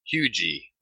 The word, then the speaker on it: Huger: There's a town and a street down here spelled H-U-G-E-R. And I have been pronouncing it Huger, but that's how you stand out as not being a local, because the locals call that Hugie.